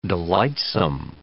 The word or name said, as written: delightsome